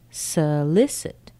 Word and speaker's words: solicit: Solicit, solicit. Solicit, solicit, solicit. Spelled with an S-O, but the sound is S.